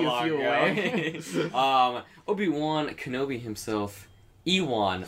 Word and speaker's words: Ewan: It's apparently pronounced Ewan.